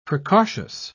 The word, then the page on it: precautious: British English